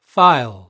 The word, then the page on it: file: British English